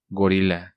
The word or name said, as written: Gorila